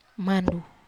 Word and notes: mandu: Wamesa

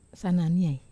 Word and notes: sananiai: Wamesa

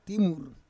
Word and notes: timur: Roon

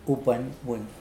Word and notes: upwenwen: Mokilese

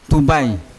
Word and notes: tubai: Yawa

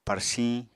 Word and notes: parsiñ: Apatani

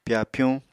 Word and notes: pyapyuñ: Apatani

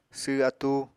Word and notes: sww atúu: apatani

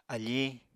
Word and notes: afiñ: Apatani

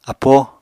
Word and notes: apóo: Apatani

